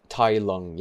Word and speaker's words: Tai Lung: So did I say his name wrong? Tai Lung or Tao Lung? – Tai Lung, yeah. – Tai Lung. – I think you might have said Tao, but that's fine.